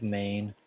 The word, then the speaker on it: Mein: Yeah, it's Craig Main. Main spelled M-E-I-N.